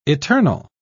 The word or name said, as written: eternal